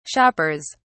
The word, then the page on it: shoppers: British English